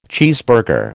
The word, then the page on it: cheeseburger: American English